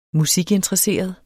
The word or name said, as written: musikinteresseret